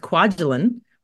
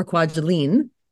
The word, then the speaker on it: Kwajalein: It's pronounced Kwajalein or Kwajalein, depending on, you know, how you feel that day. Yes, Kwaj is what we call it here. Everybody here says I live on Kwaj, and we are in the middle of nowhere, but you know, the internet gets everywhere.